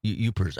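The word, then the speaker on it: Yooper: I was just reading about you guys put the accent on the first syllable. Youpers.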